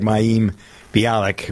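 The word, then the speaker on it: Mayim Bialik: I thought the canned laughter always kind of, although I do like Maeem Bialik, if I pronounce yeah.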